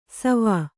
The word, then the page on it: ಸೋವಿ: sōvi